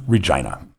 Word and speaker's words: Regina: I said the city that rhymes with fun, Regina.